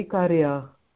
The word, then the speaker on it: Ikaria: In Greek, we say Ikaria.